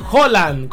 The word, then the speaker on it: Haaland: Y hablando aún de lo que dejó el tema de la copa y demás, hoy está cumpliendo años Erling Haaland, o Erling Haaland, como se pronuncia verdaderamente su apellido, en Noruega. Erling Haaland cumple 26 años, hoy 21 de julio.